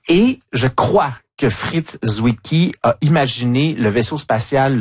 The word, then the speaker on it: Zwicky: ça s'écrit Z-W-I-C-K-Y, donc je sais pas si c'est Zwicky si on le prononce est-européennement, en tout cas, bon, c'était Fritz Zwicky, hein, c'est ça Julie? – Oui, exactement.